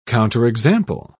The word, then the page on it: counterexample: British English